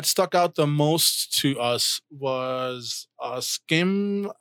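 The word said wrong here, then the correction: Schim: I'm trying to remember if I'm saying that right. Oh, um, where is it? I just saw it. Shim. Schwim. It's S S C H I M. So that's Shim.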